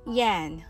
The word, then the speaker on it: yen: 75 billion yen 円は円と発音せず言えないという意味の言えんのように聞こえます。